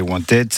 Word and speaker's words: Wantaid: Et Wanted, ça s'écrit WANTAID.